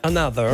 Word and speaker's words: Anotr: E invece loro vogliono essere chiamati Another. – Another. – L'hanno proprio detto, chiamateci Another, anche se si scrive Anotr.